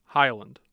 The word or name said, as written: Highland